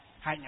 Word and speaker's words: hạnh: Hạnh ở đây tức là gọi là hành. Chữ hành nguyên á, chữ Hán là chữ hành, nhưng đọc là gì? Là hạnh.